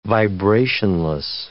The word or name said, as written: vibrationless